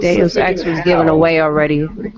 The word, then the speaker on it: Deus Ex: Like, do not ever call that game Deuces X like that. – Deus Ex.